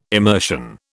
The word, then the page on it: immersion: British English